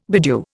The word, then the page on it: bedew: Indian